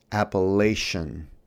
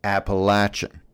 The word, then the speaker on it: Appalachian: In the first service, when I began with a long quotation from Michael Clary, I made the fatal mistake of pronouncing the mountainous region in West Virginia, Appalachian. I was appropriately corrected. It's Appalachian. Now, technically, both are correct. Culturally, one is correct.